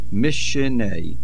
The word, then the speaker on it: Mishnei: The Dagesh Chazak compensates for the missing Nun and is pronounced Mishnei.